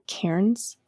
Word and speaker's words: cairns: Fairies are kind of associated with, you know, cairns. Is that how you pronounce that? Cairns? – Cairns? – Cairn? Not like a Karen.